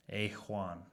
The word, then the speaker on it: Ajuan: Uh, now, how do you pronounce this project slash band? – Uh, Ajuan. – Ajuan. – Yep, you know, like you got A1 steak sauce, you got A1 shred sauce.